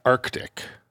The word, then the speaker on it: Arctic: I always pronounce the first C in Arctic. – Yeah, that's correct. Well, if you say Arctic, you just sound like a mouth breather.